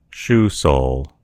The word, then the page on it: sole: American English